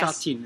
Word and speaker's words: Sha Tin: Sha Tin, is that right? – Yep.